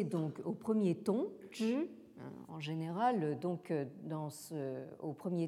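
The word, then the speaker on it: zhi (知): Mais ici, je pense qu'il est à prendre comme substantif ou comme nom, et donc il se prononce donc au quatrième ton, zhi.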